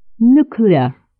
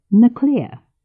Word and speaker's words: nuclear: И все же нужно сказать, что неправильное произношение некоторых слов – это зачастую признак низкого происхождения, указывающий на необразованность говорящего. Например, «nuclear» вместо «nuclear» – ядерный, или «prostrate gland» вместо «prostate gland» – предстательная железа.